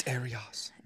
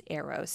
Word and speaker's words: Eros: It's Arios. – No, it's, I'm just kidding. Well, no. My research gave me a pronunciation, and it says pronounced Eros, spelled E-R-O-S, though.